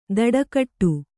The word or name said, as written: ದಡಕಟ್ಟು